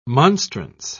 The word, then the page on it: monstrance: British English